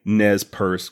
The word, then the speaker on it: Nez Perce: All I need to know is if I'm pronouncing Nez Perce correctly. She replied again, saying that yes, I was.